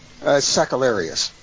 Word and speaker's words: Sakellarius: So, so a warm welcome to Stephen Sakellios. I hope I pronounced that right. Is that right? – Sakellarius. – Sakellarius. There you go. – Just a little emphasis.